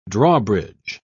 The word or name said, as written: drawbridge